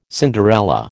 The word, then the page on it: Cinderella: American English